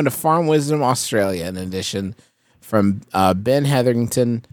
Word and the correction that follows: Hetherington: No, sent us, wrong. No way was that right. – Ben Hetherington. – Okay. – Yes, you fully skipped at least one syllable.